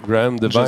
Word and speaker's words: Graham Devine: Graham Devine.